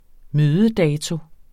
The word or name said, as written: mødedato